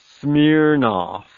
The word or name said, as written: smirnoff